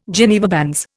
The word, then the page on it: Geneva bands: Indian